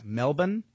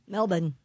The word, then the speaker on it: Melbourne: Melbourne, Australia, I guess it's Melbourne. – Melbourne. – That's the way you say it, more like Melbourne.